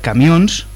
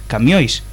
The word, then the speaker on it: camións: Escribimos co tilde na salidade por riba do o, camiões, podese ler camións ou podese ler camiois, como pronuncian no oriente da Galicia.